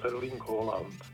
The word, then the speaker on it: Haaland: Mluvil jsem přímo s Norama v Bratislavě na poprvém turnaji a oni mi přímo potvrdili, jak se to jméno vyslovuje, a je to Erling Haaland. – Takže je to je to, co jsem říkal. A možná i bez toho D, bez toho D, ne, Haaland, jakoby, Haaland. – To úplně nevím, přesně to nechci lhát, ale určitě je to O. Je to přehlasované A, to znamená A s kroužkem, holandštině, v norštině. A čte se to jako dlouhé O.